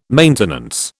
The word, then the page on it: maintenance: British English